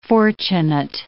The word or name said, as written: fortunate